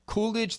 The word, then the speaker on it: Koolidge: My name is Mike Koolidge. That's spelled with a K, K-O-O-L-I-D-G-E. Find me on X, K-O-O-L-I-D-G-E.